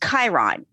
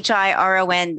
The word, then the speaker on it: Chiron: When you look at the minor planet slash comet, because it does have two designations in astronomy, it's pronounced Chiron, as if the C CH was a K.